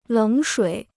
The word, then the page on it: 冷水: lěng shuǐ